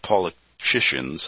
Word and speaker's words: Paulicians: The book covers, so we get into, it describes the histories of ancient separated Christians, including the Waldensians, the Lollards, the Paulicans. That sounds like Pelican, but it's Paulicians. It's P-A, you know, sometimes I can't pronounce all this stuff. So what? P-A-U-L-I-C-I-A-N-S. It gives the history of the English Bible from John Wycliffe to William Tyndale.